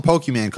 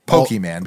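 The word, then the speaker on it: Pokémon: Then you sell the Pokemon card and then you get Pokemon, because that's how you pronounce it.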